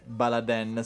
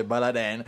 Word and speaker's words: Baladin: Esattamente, scrivendo come oggetto adesione Baladen, scritto BALDIN. – Baladin, si scrive in Baladin, ma si legge Baladen.